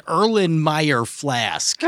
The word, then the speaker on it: Erlenmeyer flask: They enter the scene, and that's where we see an Erlenmeyer flask.